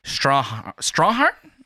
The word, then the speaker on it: Strathairn: and David Strawhart? Did I say that correctly? – You did.